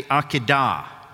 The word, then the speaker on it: Akedah: They call it the Akedah, the emphasis always in Hebrew on the last syllable.